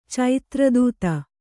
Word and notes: ಚೈತ್ರದೂತಿ: caitra dūti

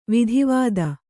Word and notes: ವಿದ್ವತ್ತು: vidvattu

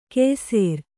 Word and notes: ಕೆಯ್ಸೇರ್: Kannada

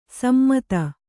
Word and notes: ಸಮ್ಮತ: sammata

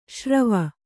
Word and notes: ಸುರ್ವು: kannada

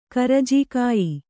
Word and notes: ಕರ್ಜಿಕಾಯಿ: karjikāyi

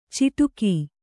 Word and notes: ಚಿಟುಕೀ: ciṭukī

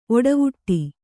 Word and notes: ಒಡವುಟ್ಟು: Kannada

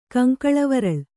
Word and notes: ಕಂಕಳವರಳ್: kaŋkaḷavaraḷ

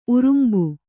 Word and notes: ಉರುಂಬು: urumbu

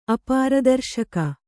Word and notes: ಅಪಾರದರ್ಶಕ: apāradarśaka; kannada

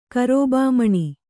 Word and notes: ಕಾರೂಬಾಮಣಿ: kannada

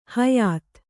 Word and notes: ಹೊಯ್ತ: hoyta

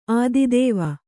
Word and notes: ಆದಿದೇವ: ādidēva

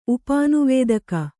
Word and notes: ಉಪಾನುವೇದಕ: upānuvēdaka

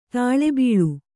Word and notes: ತಾಳೆಬೀಳು: tāḷe bīḷu

